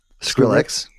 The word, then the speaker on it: Skrillex: Is it Skrillex? – Skrillex.